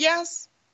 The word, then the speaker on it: Yaz: Sarah, did you ever pronounce it Yaz? – Is.